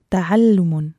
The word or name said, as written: تَعَلُّمٌ